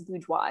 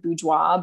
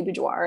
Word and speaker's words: boudoir: So the correct way to say it is boudoir. It's a French word, boudoir, but Americans, for some reason, we hang on to the R, so we just say boudoir.